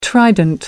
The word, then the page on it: trident: American English